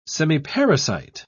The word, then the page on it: semiparasite: British English